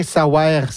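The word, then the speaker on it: Saouère: Je dis bien « savoir », c'est parce que c'est appelé S-A-O-U-E, accent grave, R-E. Mm-hmm. Donc, c'est appelé de manière phonétique, comme on le prononce.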